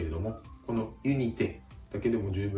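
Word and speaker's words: unité: ですから、ユニテ、ユニテ、多少てのところを強く発音してあげるとフランス語の発音になります。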